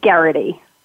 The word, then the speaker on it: Geraghty: It's Geraghty.